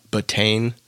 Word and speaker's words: betaine: The other, supplement that can be within these matrix is called, I pronounce it betaine. It's BETAINE.